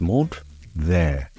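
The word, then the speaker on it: menthe verte: Spearmint in French is pronounced menthe there and spelt menthe, M-E-N-T-H-E, and ver, V-E-R-T-E.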